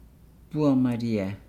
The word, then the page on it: bua maliaa: Nukuoro